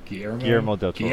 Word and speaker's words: Guillermo del Toro: How the fuck do you say his name? – Guillermo del Toro. – Guillermo. There we go. Yeah, del Toro.